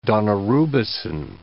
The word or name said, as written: daunorubicin